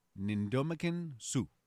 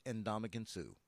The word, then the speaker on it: Ndamukong Suh: Ndamukong Suh. – Close. It's Ndamukong Suh.